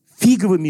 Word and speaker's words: фиговыми: Они покрывают свое тело фиговыми листочками. Стараюсь правильно ставить ударение. Потому что если поставить неправильно, будет фиговые листочки.